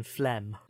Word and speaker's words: phlegm: But So like the G in phlegm. – Well, not when you say it.